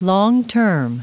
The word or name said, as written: long-term